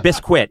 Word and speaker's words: biscuit: It's not biscuit. It's just biscuit. – There's a U inside this, Conan. – Yes, I know, but we call it a silent U, a U that has been bullied into silence.